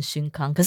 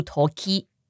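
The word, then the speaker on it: 瞬間: そんな時が，这个歌词比较特别的是，它是そんな是那样的，然后后面那个 toki 它其实汉字是写瞬间，所以我一开始看到都很想念瞬康，因为的确是有瞬康这个念法，一般来说都会念成瞬康，可是这首歌它的这个假名的注音是注 toki。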